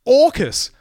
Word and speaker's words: AUKUS: That's the acronym for this alliance, which is being pronounced AUKUS, which is the Orc God of War in D&D.